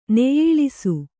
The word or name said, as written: ನೆಯ್ಯಿಳಿಸು